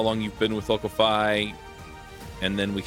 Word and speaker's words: Locofy: Locofy.